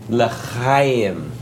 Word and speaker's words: L'chaim: No, no, Augie, it's Lachaim. Lachaim.